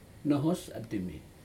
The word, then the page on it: nohos atimi: Aneityum